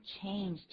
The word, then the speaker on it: change: I have $50 in change. 请注意 change 的发音，不要念 change，它的母音很长的。